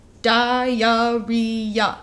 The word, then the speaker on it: diarrhea: I was like, I'm going to count the syllables in diarrhea on my fingers, and I want you to watch. And I went, diarrhea. – And held up four fingers by the end.